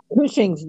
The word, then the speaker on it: Cushing's: Cushing's disease. – Cushing's. Like pushing, only Cushing? – Uh-huh.